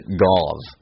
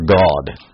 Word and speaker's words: Gad: It's literally, you pronounce it Gav or God.